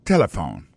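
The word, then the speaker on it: telephone: N'a pas remarqué que l'accent tonique l'a tombé sous premier syllabe là. Si nous besoin de dire bien, c'est téléphone.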